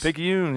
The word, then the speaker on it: Picayune: Is that how you said Picayune? – Yes. Picayune. Yeah, that's it.